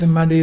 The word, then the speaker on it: Irrumardi: It's a muddy, it's brim, the fish brim, and it's spelt IRRUMARDI.